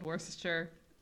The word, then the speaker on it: Worcestershire: attending uh, called Worcestershire.